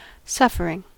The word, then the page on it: suffering: American English